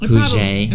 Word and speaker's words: Puget: But it's pronounced Puget.